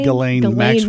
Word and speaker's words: Ghislaine: What does Ghislaine, I always want to, is that how you say your name? – Ghislaine Maxwell.